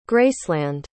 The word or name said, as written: graceland